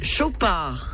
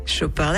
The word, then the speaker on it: Chopard: But hey-ho, the answer I was looking for is Chopard. – Chopard, that was easy. – Thank you. – Great pronunciation. Very good in French.